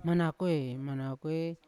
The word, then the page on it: manakoe: Yawa